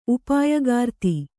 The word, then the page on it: ಉಪಾಯಗಾರ್ತಿ: upāyagārti